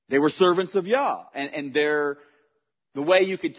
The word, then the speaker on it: Yah: I mean, no one argues, generally speaking, about the short poetic prefix being Yah, because everyone knows that Hallelujah is the same in all languages, right?